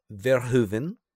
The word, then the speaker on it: Verhoeven: And I did get from, I got the official pronunciation to make sure that we were accurate. Verhoeven.